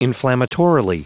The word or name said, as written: inflammatorily